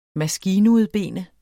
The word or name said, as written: maskinudbene